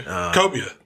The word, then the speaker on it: Cobia: and he brought me back some swordfish and some cabo. Am I saying that right? Some kind of fish. I don't remember. Said it. Call it the ribeye of the sea. – Oh, okay. Cobia.